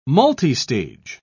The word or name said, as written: multistage